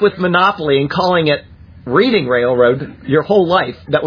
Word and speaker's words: Reading: And Reading. I said Reading. I know that it's Reading. And I convinced myself that I was going to say it correctly, and then I said the opposite. It is Reading, Pennsylvania, like the Reading Railroad on the Monopoly set.